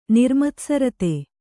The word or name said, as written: ನಿರ್ಮತ್ಸರತೆ